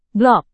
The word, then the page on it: blok: indonesian